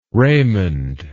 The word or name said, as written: raymond